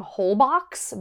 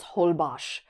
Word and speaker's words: Holbox: I was saying Holbox because that's how it's spelled. And they're like, oh, it's actually a Mayan word. And in Mayan, the X is pronounced like an SH. So it's Holbosh, which, like, I knew that an X is an SH.